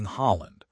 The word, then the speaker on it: Haaland: In Fantasy Premier League, spending $15.5 million on someone like Erling Haaland, pronounced H-O-H-L-N, forces you to make painful trade-offs.